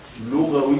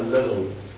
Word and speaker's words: لغوي: يقول رحمه الله تعالى: الإعراب له معنى يعني أحدهما لغوي، ولا نقول لغوي إذا نطقناها، لغوي بضم اللام لغوي، بعض الشباب ممكن يقرأها اللغوي من اللغو، وهذا معي.